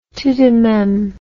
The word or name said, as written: tout de meme